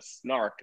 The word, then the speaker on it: Snark: Novar discovers a dying Emperor Stoat of the Snark, that's how you pronounce it, who says that a shapeshifter killed him.